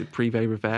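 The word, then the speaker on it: Privé Revaux: The Privé Revere, am I saying that right? – Yeah.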